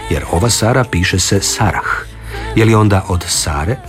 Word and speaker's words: Sarah: U nominativu i hrvatsko ime Sara i strano ime koje se piše Sarah izgovaraju se jednako.